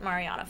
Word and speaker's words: Mariana: Lots of people are saying Mariana, lots of people are saying Mariana, but I pronounce it Mariana Foster, so.